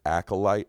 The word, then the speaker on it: Acolyte: Acolyte, am I saying that right, Will? – Yeah, of course.